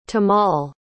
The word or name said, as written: tamal